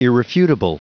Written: irrefutable

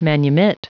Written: manumit

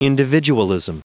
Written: individualism

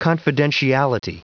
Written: confidentiality

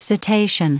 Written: cetacean

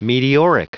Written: meteoric

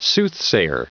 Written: soothsayer

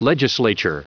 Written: legislature

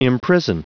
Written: imprison

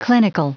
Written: clinical

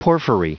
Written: porphyry

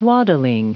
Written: waddling

